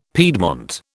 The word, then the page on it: piedmont: British English